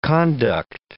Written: conduct

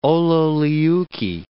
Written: ololiuqui

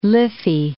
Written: Liffey

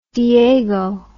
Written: Diego

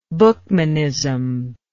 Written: buchmanism

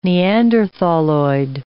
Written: Neanderthaloid

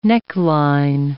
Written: neckline

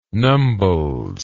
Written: nombles

